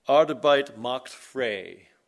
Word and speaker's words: Arbeit macht frei: And it's pronounced like this: Arbeit macht frei. Arbeit macht frei.